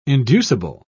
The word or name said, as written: inducible